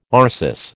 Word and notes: arsis: American English

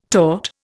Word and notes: taut: Indian

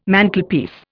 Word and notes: Mantelpiece: Indian

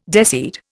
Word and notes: deseed: Indian

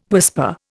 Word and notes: whisper: Indian

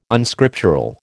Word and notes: Unscriptural: American English

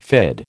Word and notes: fid: American English

